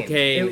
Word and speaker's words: Spokane: I wasn't even saying it correctly until like a week ago. – Oh. – When I was going to Spokane. – Spokane.